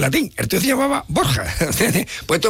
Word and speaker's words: Borja: No, los Borgia son los Borja. Lo que pasa es que Borja en valenciano es Borgia. Y los italianos, para poder pronunciar Borgia, tenían que escribir Borgia. Y al final ha quedado, nosotros decimos, en vez de Borja, los Borgia.